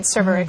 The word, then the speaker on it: Cervarix: Cervarix? – Mm-hmm. – Is that how you pronounce right. – it? – Yeah.